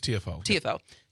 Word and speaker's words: Tiafoe: it's Francis, is it Tiafoe? Is that how you pronounce it? – Francis Tiafoe.